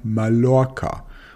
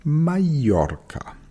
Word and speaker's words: Mallorca: Diejenigen, die keine Ahnung vom Spanischen haben, sagen Mallorca. Und diejenigen, die es komplett übertreiben, sagen Mallorca. Aber korrekt wäre Mallorca.